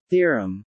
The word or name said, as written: theorem